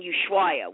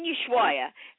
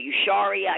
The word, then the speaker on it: Ushuaia: First of all, the whole time I was there, I couldn't pronounce it. I kept calling it Usharia. I called it everything but what it was.